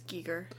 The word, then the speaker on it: Giger: Giger. – I think it's pronounced. No, it is Giger.